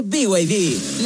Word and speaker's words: BYD: Se escribe BID, pero se dice BYD.